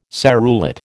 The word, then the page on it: serrulate: American English